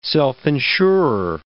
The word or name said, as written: self-insurer